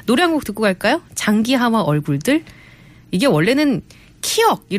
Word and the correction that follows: 키읔: 이렇게 읽어야 되는데요. 네. 크라고 읽더라고요. 크 들어봅니다. 네. 키역이 아니고 키읔입니다.